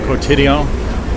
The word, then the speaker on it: Quotidien: Quotidien. You know, pronounce the last the last letter.